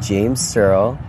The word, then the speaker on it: James Searle: I'm here with James Searle.